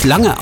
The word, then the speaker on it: Lange: Jo, und er spricht sich wirklich Lange aus, denn seine Eltern sind deutschstämmig.